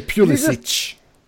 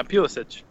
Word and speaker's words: Pulisic: And, whoa, that was an interesting pronunciation of it. – Well, I want to say Pulisic, even. – Well, that's what he wants you to say. – Yeah. – Yeah, Pulisic.